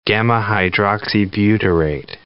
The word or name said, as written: gamma hydroxybutyrate